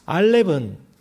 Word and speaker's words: 알렙: 알렙은 기본적으로 아 발음이 납니다.